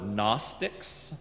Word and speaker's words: Gnostics: And these false teachers later came to be known by the word Gnostics. And that's actually spelled with a G. Silent G. G-N-O.